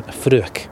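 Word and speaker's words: Fraoch: We just didn't quite know how to pronounce it, but we Fruach is how you pronounce that word for heather in Gaelic.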